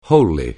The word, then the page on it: wholly: anglais